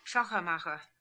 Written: Shachermacher

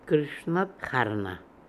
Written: Krishnatkharna

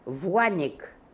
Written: Vuanik

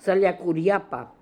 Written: Saliakooriapa

